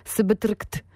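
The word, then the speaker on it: SBTRKT: śmiesznie się pisze, Subtract, czyta się Subtract i tam również Yukimi Nagano gościnnie, to był całkiem spory przebój, Wildfire sprzed kilku lat.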